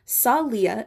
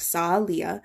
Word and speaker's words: Salia: Salia is actually how you pronounce it, like Salia.